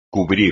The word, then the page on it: cubrir: espagnol